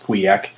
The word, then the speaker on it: Pauillac: That's spelled P-A-U-I-L-L-A-C. It's pronounced Pouillac. Saint-Julien, Listrac, Moulis, and that's spelled M-O-U-L-I-S, Margaux, Pessac-Léognan, and Grave.